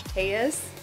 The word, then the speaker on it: Telles: And I'm going to make sure to say your name right, but it's at Josh Tellez. – Tellez, yeah. T-E-L-L-E-S.